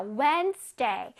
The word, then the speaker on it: Wednesday: 자, 저기서 D는 소리를 내지 않습니다. 오케이. 그냥 무늬만 D가 있는 거예요. 자, Wednesday.